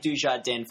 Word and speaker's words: Dujardin: I've been saying Dujardin for ages now. I just think he's the kind of guy they're going to go with. And you're finally pronouncing it correctly.